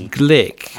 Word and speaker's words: Gleick: Oh, James Glick. Glick, yeah? – Glick, yeah. – Glick, okay. It looks like Glick.